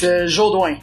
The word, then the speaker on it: Jodoin: It's Jodoin.